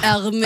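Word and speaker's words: Hermès: Er det sånn det uttales? – Neida, Hermé-veske. Okay. Hermé-veske. Jo, man sier vel Hermé-veske. – Vet jo ikke dette jeg. Det er jo et fransk merke. Man sier Hermé-veske.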